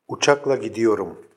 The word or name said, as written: ucakla gidiyorum